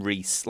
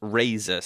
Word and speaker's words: Razer: they were called we we called them Reese. Like the reviewers that got the early ones called them Reese, but it was actually pronounced Razor.